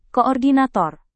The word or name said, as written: koordinator